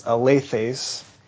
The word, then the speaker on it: alethes: And the word in Greek is pronounced alethes.